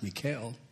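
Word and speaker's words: Michal: Spelled like Michael, but I'm told it's pronounced Mikael.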